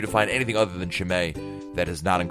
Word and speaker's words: Chimay: One, the only one that I can really think of off the top of my head these days is, I hope I'm pronouncing this right, Chimay, C-H-I-M-A-Y. It's like a large, ridiculously large bottle of beer.